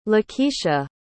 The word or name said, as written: Lakisha